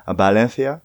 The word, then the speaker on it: Valencia: Ich habe Akzent, war ja auch mal im Homestay-Programm in Valencia, man sagt ja Valencia, also das V spricht man als leichtes B aus, Valencia.